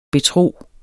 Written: betro

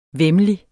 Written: væmmelig

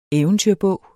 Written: eventyrbog

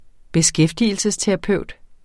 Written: beskæftigelsesterapeut

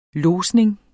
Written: lodsning

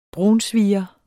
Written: brunsviger

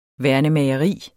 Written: værnemageri

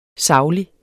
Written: SAGLIGT

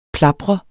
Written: plapre

